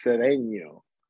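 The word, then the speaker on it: Sedeño: You don't respect my tilde. My name is Sedeno.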